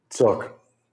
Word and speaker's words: Sook: How do you pronounce it? Sook? – Sook.